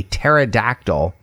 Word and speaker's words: pterodactyl: So this joke is, why is it impossible to hear a pterodactyl, that's a dinosaur, going to the bathroom? – Why is that? – Because the P is silent. Pterodactyl, P-T-E-R-O-D-A-C-T-Y-L.